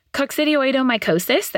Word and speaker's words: Coccidioidomycosis: Coccidioidomycosis, that we covered on this podcast, is also thermally dimorphic.